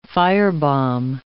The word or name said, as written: firebomb